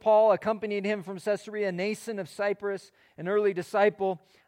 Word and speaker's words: Mnason: And so it's interesting, Mnason, the M is silent.